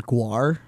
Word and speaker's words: Gwar: Gwar.